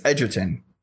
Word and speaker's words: Egerton: Is it Egerton or Edgerton? – I think it's Edgerton. – Edgerton. It's confusing these British people. – I've heard more people pronounce it Edgerton. – It's spelled weird. It's spelled E-G-E.